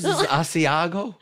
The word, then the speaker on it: Asiago: But look, what is Asiago? – That is, okay.